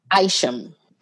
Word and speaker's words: Isham: I think it's Isham. – Isham? Go with whatever you call it. – I'm pretty sure it's Wayne Isham. – Wayne Isham. I'm going to tell you why I picked this video for the list for sure.